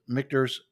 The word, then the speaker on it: Michter's: My four is Michter's Straight Rye.